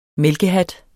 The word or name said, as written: mælkehat